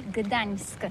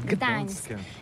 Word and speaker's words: Gdańsk: Alors d'abord, comment prononce-t-on bien? Ça s'écrit G-D-A-N-S-K. On dit Gdansk ou Gdansk, on prononce le G ou pas? – Oui, c'est pas très facile pour des étrangers, mais remarque bien que la langue polonaise, c'est la langue où on prononce chaque lettre qu'on écrit. C'est pourquoi on dit Gdańsk.